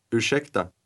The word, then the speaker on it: ursäkta: Ursäkta. – Note how the speaker's voice rises a little at the end of that word.